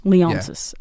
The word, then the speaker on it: Leonsis: So, I might butcher this name. Ted Leonsis? Do you know him? – Leonsis. – Yeah.